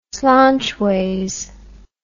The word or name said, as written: slonchways